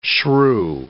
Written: shrew

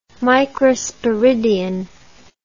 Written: microsporidian

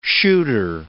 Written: shooter

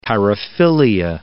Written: paraphilia

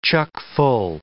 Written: chockful

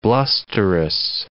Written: blusterous

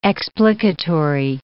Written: explicatory

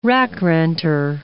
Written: rack-renter

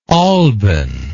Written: alban

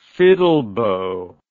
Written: fiddlebow

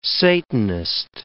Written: satanist

